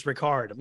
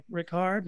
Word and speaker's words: Rickard: And I misread the way he wrote Rickard, and I thought it was Ricard, like Jean Luc yeah. Picard of you know Star Wars, uh, Star Trek: The Next Generation. I, I mispronounced it. My boss don't. I was in the other room. Don't know where he came from. Materialized out of nowhere. It's like, what'd you call him? It's like Rickard, and he was just like, no, it's Rickard.